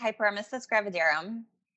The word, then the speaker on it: hyperemesis gravidarum: So it's pronounced hyperemesis gravidarum.